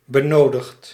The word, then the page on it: nécessaire: France (Paris); [ne.se.sɛʁ]